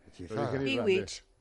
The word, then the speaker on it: Bewitched: Bewitched.